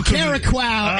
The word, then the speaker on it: Curaçao: Is it Curaçao? – Yeah. – Exactly. That's what I said.